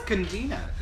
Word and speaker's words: cantina: I think it was Cantina. – It's Cantina. – Is it? – Yeah. I work in an Italian restaurant. We have a Cantina.